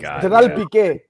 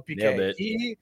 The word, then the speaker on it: Gerard Piqué: Gerard Piqué. – Yeah, there you go, Piqué.